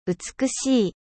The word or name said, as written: 美しい